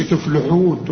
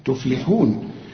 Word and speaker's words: تفلحون: هنا كل الكلمة أو الكلمة دي والكلمة الأخرى مرققين، لكن اللام هنا تفلي مرققة ومكسورة، ما تقولش تفلحون تفلولو، لا تفلحون.